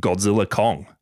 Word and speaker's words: Godzilla x Kong: The director, Adam Wingard, just calls it Godzilla Kong, The New Empire. – Oh. – The X is silent, apparently.